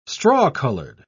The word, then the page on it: straw-colored: British English